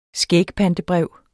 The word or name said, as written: skægpantebrev